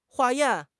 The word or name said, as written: 化验